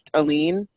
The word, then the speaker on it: Aileen: My name is pronounced Aileen. But so many people say if my mom is saying it, it's going to have that hard A on it, because she's usually trying to get my attention. It's going to be Aileen. So all of that is fine.